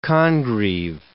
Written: Congreve

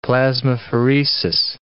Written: plasmapheresis

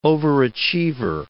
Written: overachiever

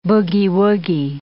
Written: boogie-woogie

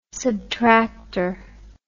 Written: subtractor

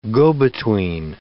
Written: go-between

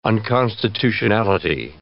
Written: unconstitutionality

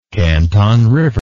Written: Canton River

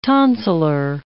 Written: tonsillar

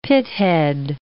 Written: pithead

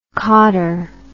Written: cauter

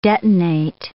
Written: detonate

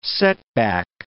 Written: setback

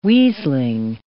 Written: weaseling

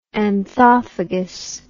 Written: anthophagous

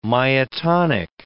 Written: myotonic

